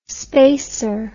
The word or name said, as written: spasur